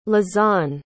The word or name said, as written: lezane